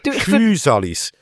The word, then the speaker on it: Physalis: Wird Physalis ausgesprochen, also lang. Physalis.